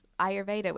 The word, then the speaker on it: Ayurveda: You must know my friend Jasmine Hemsley, the queen of Ayurveda, which I can never say that word. Did I say it right? – Uh, you said it right, yes.